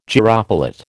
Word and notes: gyropilot: American English

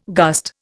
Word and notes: gust: Indian

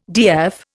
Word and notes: DF: Indian

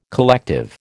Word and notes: collective: American English

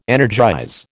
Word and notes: energize: American English

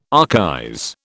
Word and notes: archaize: British English

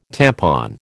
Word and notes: tampon: American English

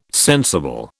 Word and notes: sensible: British English

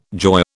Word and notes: joy: British English

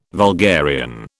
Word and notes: vulgarian: British English

